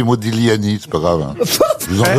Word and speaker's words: Modigliani: Vous dites quand vous voulez, mais on doit pas prononcer le G. – Non, c'est Modigliani.